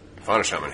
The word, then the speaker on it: Fauna Shaman: Fauna Shaman has not. You're saying it. You've been saying it right.